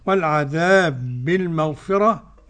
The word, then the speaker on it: بالمغفرة: والعذاب بالمغفرة، بالمغفرة قرأ ورش بترقيق الراء. – نعم، بالمغفرة. – والباقون بالتفخيم. – نعم. – والعذاب بالمغفرة قرأ بالإدغام السوسي عن أبي عمرو. – كيف يكون النطق فضيلة الشيخ؟ – والعذاب بالمغفرة، والعذاب بالمغفرة، والعذاب بالمغفرة.